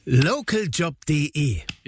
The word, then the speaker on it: Localjob.de: Localjob.de. – Ja, aber die Betonung mehr auf Local.